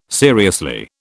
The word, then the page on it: seriously: British English